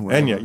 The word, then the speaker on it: Enya: Well, I listen to a lot of Anya, Enya, Enya. You know it so much that you spell it, you pronounce it incorrectly.